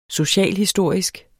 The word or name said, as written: socialhistorisk